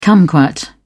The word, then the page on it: kumquat: British English